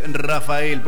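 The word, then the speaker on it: Raphael: Se lee Rafael, por eso yo cuando recién presentaba decía Rafael o Rafael.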